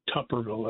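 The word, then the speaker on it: Tuberville: And I don't think Senator Tommy Tupperville, that's the correct pronunciation of his name, from Alabama, I don't think he was being racist at all.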